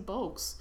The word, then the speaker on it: Bogues: I love Muggsy Bogues. But he was really short. – Yeah, and he rhymes with bugs, and this is what's bugging me right now.